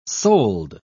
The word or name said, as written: sold